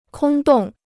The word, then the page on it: 空洞: kōng dòng